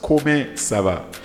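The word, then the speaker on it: Comment ça va: But in French, it is pronounced Koma Sava, which means how are you.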